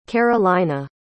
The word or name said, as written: carolina